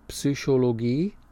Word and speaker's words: Psychologie: For the Aussprache, pronunciation, Psychologie, with the P and the umlaut for the Y, say it again, Psychologie, klinische, clinical, klinische, Master, or Master, insgesamt, Monate, stress on Mon.